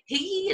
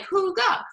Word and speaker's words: hygge: It's spelled H-Y-G-G-E. It's actually a Danish concept. So and when you look at it, you're like, people are like, is it Higgy? Is it Hugu? How do you say it? It's Huga. I was like, Huga, Huga is how we kind of explain it to folks.